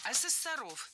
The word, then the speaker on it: ассессоров: Ассессоров неверно. Правильно, ассессоров. Существительное «ассессор» имеет неподвижное ударение на втором слоге. Ну а раз оно неподвижное, значит, мы его не двигаем при изменении слова. Ассессоров, ассессорами, ассессором.